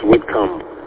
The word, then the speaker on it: Whitcomb: Oh, it's pronounced Whitcomb. I used to live on Whitcomb Street in Detroit. Spelled the same way. But that's a common mistake.